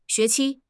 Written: xue2 qi1

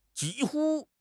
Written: ji1 hu1

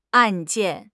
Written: 案件

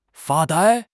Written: 发呆

